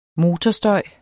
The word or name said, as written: motorstøj